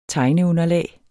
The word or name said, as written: tegneunderlag